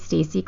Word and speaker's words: Stacy: Hi, my name is Stacy Clark. Stacy rhymes with Casey, Lacey, Macy, and Racy.